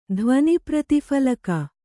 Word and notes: ಧ್ವನಿಪ್ರತಿಫಲಕ: dhvani prati phalaka

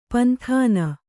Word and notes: ಪಂಥಾನ: panthāna

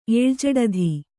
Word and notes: ಏಳ್ಜಡಧಿ: kannada

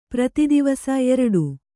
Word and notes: ಪ್ರತಿದಿವಸ: Kannada

